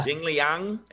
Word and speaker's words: Jingliang: Jingliang? Is that how you say it? – Yeah.